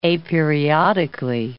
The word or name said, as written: aperiodically